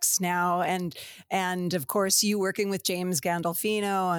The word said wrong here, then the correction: Gandolfini: and have I said it right? Gandolfini? – Gandolfini. Yeah, you almost Gandolfini. Had. I knew it was wrong as soon as I said it.